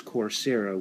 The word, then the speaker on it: Coursera: What's funny about, again, the organization is Coursera, which is spelled like course, C-O-U-R-S-E, with an R-A at the end, Coursera.